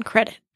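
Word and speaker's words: Crédit Mobilier: It was called Credit Mobilier. – Credit Mobilier? – Mm-hmm. With the accent on the E in credit.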